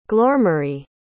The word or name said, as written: glormarie